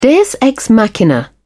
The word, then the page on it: deus ex machina: British English